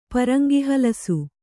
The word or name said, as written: ಪರಂಗಿಹಲಸು